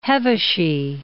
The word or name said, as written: Hevesy